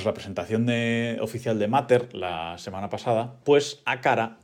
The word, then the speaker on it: Matter: A partir de ahora va a ser Mater, como se, como se escribe en castellano, pues lo voy a pronunciar así, Mater.